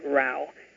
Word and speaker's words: Rao: Rao rhymes with cow, although people usually pronounce it row. I have to admit that I often say it that way myself, since it's the more traditional pronunciation. Plus, pronouncing my name my own way makes me feel like a bit of a rebel.